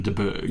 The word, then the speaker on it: de Bourgh: Lady Catherine de Bourgh.